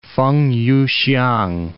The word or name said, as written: Feng Yu-hsiang